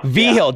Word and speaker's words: Vigil: I believe I pronounced that correct, I hope. – Vigil, Dr. Joe Vigil.